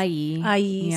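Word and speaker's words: ayi (阿姨): how do you say auntie in Chinese? – Uh, ah, ayi.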